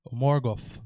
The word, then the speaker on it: Morgoth: Então, com a com a, a própria queda de Morgoth, Morgoth, né, que é a pronúncia correta,